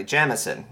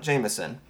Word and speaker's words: Jameson: It was Old Bushmills Distillery, John Jamieson and Sons, and that is how it's pronounced, apparently. Jamieson, not Jameson.